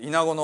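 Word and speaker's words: 蝗: で、豹の災いの時にはもう避難するものも起こって、そしてイナゴ、これは虫に皇帝の皇という字を書いてイナゴと読むそうですけど、イナゴの災いの時にはもう助けてくれとファラオのところに懇願に行きましたね。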